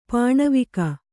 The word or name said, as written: ಪಣವಕ